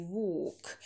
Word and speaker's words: walk: В первом аудиосообщении отметила walk, где вокабуляр walk, долгий о, не произносите л, немая там элька, silent l. Она есть как буква, но как звука ее нет. Образует звук о, долгий walk.